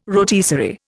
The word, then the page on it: rotisserie: Indian